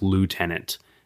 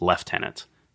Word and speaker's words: lieutenant: In the United States, whether it's, you know, the military rank or anything like that, it's pronounced lieutenant. But in Canada and the United Kingdom, it's pronounced lieutenant. Spelled exactly the same. It's just one of those differences between Canada and the U.S.